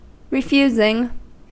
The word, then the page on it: refusing: American English